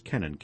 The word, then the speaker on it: Kennan: Kennan, or actually, I believe most people pronounce this Kennan, K-E-N-N-N, K-E-N-N-A-N.